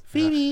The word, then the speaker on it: Phoebe: Phoebe spricht man das aus. – Phoebe. – Ja, wie Phoebe von Friends.